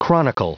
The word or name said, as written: chronicle